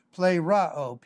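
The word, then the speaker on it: pleroo: The Greek word here is plero, P-L-E-R-O-O, P-L-E-R-O-O. The first O is pronounced ah, so plero. The E is pronounced like long A, P-L-E-R-O-O, plero.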